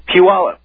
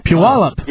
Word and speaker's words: Puyallup: Is that actually how you pronounce the name of where you're from? – It's Puyallup. – Puyallup. – Yeah. – Not pull y'all up? – That's no. How... Okay, yeah, there's not an extra L in it. Puyallup.